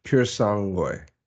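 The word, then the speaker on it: Purosangue: So the other thing that came out is uh that last week was the uh, Ferrari Persangue. Did, did I say it right? Pure, pure, pure sangue. Pure sangue, yeah.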